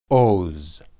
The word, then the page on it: oz: Hebrew